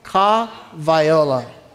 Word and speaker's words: Ka Waiola: Ka Waiola.